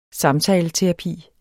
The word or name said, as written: samtaleterapi